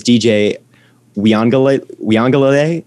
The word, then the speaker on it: Uiagalelei: Also, my Heisman pick is DJ Uiagalelei.